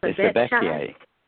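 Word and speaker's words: Sibbecai: Is it Sebechai? – It's Sebechai.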